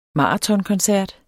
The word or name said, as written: maratonkoncert